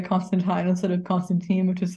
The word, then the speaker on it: Constantine: Oh, another thing is that his name is pronounced Constantine, not Constantine, even though Constantine is the common pronunciation.